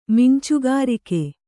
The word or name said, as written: ಮಿಂಚುಗಾರಿಕೆ